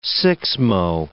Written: sixmo

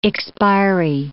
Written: expiry

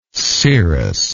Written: cirrhus

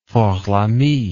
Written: fort lamy

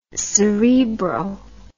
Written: cerebro

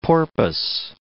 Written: porpoise

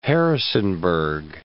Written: Harrisonburg

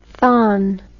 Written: thon

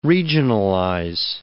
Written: regionalize

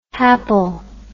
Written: hapl

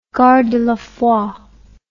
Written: gardez la foi

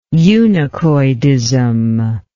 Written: eunuchoidism

